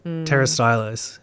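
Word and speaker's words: Pterostylis: And then the silent P, that always gets me. – Yep. – Like pterostylus, and you look at it and it's like, oh yeah, pterostylus, and it's like, that is not...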